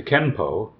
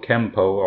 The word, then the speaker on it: Kenpo: And then we get to Kenpo. Kenpo, Kempo, all the same word. Apparently it's the same spelling. The kanji just pronounced in different ways.